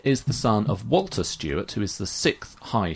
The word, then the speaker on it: Stuart: As well, but it's the third high steward in Scotland that adopts Stuart as a surname.